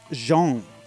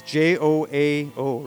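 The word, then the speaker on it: João: And I'm pretty sure in Portuguese it's pronounced Jean, so it's like John, basically. – Yeah. – But everybody and all the gringos say Joao.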